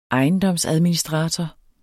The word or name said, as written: ejendomsadministrator